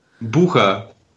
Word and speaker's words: Butcher: You butchered my name, but so you tried. Hi there. Bucha. – Bucha. Sorry. – Yeah. No worries. It's German, so.